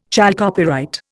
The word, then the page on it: chalcopyrite: Indian